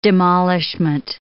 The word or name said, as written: demolishment